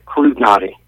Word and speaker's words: Klutinoty: Your last name is spelled or pronounced Klutinoty. – Yeah, it's Klutinoty. – Klutinoty.